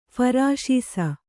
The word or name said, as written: ಫರಾಶೀಸ